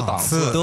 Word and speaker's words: 档次: 嗯，哦，档次哎，这个是语文里面一个考点啊。 – 呃对，多音字，我们都说档次。